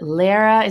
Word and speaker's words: Lara: And my first name always threw people off, L-A-R-A. – Right. – People still ask me, Michelle, they're like, so how do you say, is it Lara? Is it, it's Lara, but whatever.